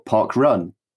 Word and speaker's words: parkrun: The small a small uh, semantics question: Is it pronounced Park Run or Park Run? – It's Park Run.